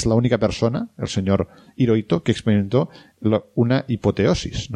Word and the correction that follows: apoteosis: ¿no? Que se le contó de una apoteosis, ¿no? ¿Lo he dicho bien? – Sí, sí, sí.